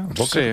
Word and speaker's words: Böker: No, una empresa familiar alemana, se llama Böker, ¿se pronuncia? Böker, sí. Con la O con dos puntitos, es Böker.